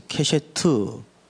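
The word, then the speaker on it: 캐셰트 (קשת): 캐셰트. 발음을 좀 잘 들으십시오. 또 괜히 욕으로 듣지 마시고.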